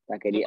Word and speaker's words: Taqueria: Hey, how do you pronounce it? – Zeke Stackeria. Z E K E and then T A Q U E R I A S. Stackeria. Oh no, like, without the S.